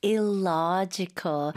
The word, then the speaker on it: illogical: Right, illogical, right? L-O의 강세가 많이 가고요. 명사로 logic 하면 그게 논리가 되겠죠.